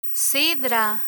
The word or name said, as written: sidra